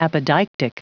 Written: apodeictic

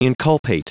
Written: inculpate

